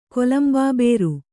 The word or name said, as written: ಕೊಲಂಬಾಬೇರು